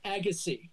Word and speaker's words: Agassi: How how do you pronounce it? – Agassi. – Because Santo, sorry, the Colonel says Agassi.